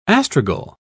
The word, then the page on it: astragal: British English